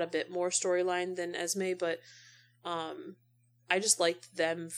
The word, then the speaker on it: Esme: Is it Esme? Is that how Esme. – You pronounce it? Yeah. Yeah, the only reason why I know that is because of Renesme.